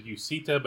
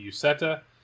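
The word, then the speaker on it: Uceta: Last year, the preseason like pronunciation guide or whatever, it said Yuseta, but it's actually Yuseta. So good to know.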